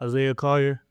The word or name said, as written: Isaiah Collier